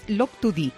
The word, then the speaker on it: Loctudy: Ya me ha corregido aquí el compañero Barú Domínguez, ¿eh? Porque no sabía yo muy bien la pronunciación de esta localidad de la Bretaña francesa. Es Loctudy, ¿eh?